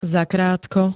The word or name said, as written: zakrátko